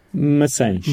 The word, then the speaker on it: Maçães: It's Macias, right? – Massage. – Massage. – A little softer.